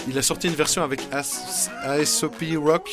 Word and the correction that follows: ASAP Rocky: je sais pas comment ça se prononce. ASAP Rocky. ASAP Rocky, voilà.